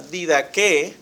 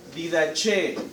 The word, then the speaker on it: Didaqué: Bueno, algunos estudiosos me preguntaba, perdón, Nicolás me preguntaba, ¿cuál es el nombre correcto? ¿Cómo se pronuncia? Bueno, se pronuncia Didaqué. Algunos le llaman, ¿sí? Didache. A mí no me gusta ese nombre. No me gusta, se puede traducir también así, pero normalmente el acento se la pone en la segunda E.